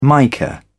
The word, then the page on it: mica: British English